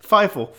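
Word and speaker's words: Fievel: Feifel.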